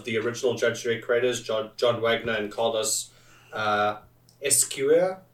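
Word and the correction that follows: Ezquerra: Just like, not sure how you pronounce that one. – Yo, Esquera. – Esquera, yeah. There we go.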